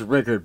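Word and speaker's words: Rueckert: It's pronounced Rickert, but it's R-U-E-C-K-E-R-T. – R-U-E.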